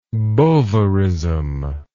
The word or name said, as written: bovaristic